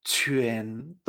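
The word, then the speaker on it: quán: Algo completo, se dice Q-U-A-N-D-O-U, en segundo tono y después primero. Se pronunciaría Chuan Fíjate, Q-U-A-N no es Chuan, sino que es Chuen, Chuan. yuen. Esta terminación, ¿por qué? Porque tenemos la Q delante.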